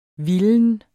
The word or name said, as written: vilden